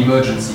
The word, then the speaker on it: Emerge and See: Emergency Medienkunstfestival mit Emergency. Das ist ein Medienkunstfestival mit. Das wird geschrieben Emerge and See. Genau. Ah. – Äh, mit Aber Lectures. – ausgesprochen wie Emergency.